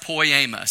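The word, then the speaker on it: poiema (ποίημα): It's pronounced poiema.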